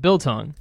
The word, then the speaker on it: Biltong: Did I say that right? Biltong. – Absolutely perfect.